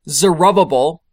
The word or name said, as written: Zerubbabel